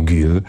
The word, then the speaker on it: Gil: Dostałem też list od pana Bartka, który usłyszał ostatnio u Petersona wymowę Jill Scott Heron. No i rzeczywiście, tak jak sprawdzałem, to Amerykanie mówią Gil. Jest to dla mnie dosyć ciekawe. Gdzieś spotkałem się rzeczywiście z taką też wymową Gil i tak to powinno się czytać, Gil Scott Heron.